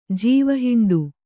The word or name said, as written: ಜೀವಹಿಂಡು